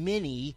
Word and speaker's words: Meni: And we would say it from the Hebrew transliteration, many, M-E-N-I, would be a good way to spell that in English.